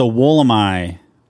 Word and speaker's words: Woolamai: It's not the Woolamai or Wollamai. – Nope. – It is the Woolamai. – That is the Australian pronunciation, which I think is the best we can do.